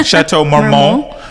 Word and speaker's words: Chateau Marmont: I think that's right. Pronouncing that? – Yeah, I think you got it. Yeah, Chateau Marmont.